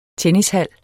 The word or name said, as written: tennishal